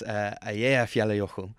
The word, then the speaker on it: Eyjafjallajökull: I think it's Eyjafjallajökull.